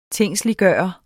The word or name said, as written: tingsliggjort